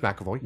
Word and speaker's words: McAvoy: No, James McAvoy.